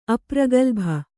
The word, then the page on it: ಅಪ್ರಗಲ್ಭ: apragalbha